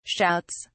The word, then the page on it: shouts: British English